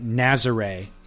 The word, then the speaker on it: Nazaré: He started it a year ago, but Nazaré is the official pronunciation.